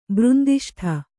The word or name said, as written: ಬೃಂದಿಷ್ಠ